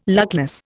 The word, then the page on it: luckless: Indian